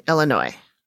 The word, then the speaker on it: Illinois: states of Iowa and Illinois, Davenport and Bettendorf, and southeastern Iowa and Rock Island, Moline, and East Moline in northwestern Illinois. – It's pronounced Illinois.